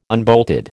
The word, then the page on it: unbolted: American English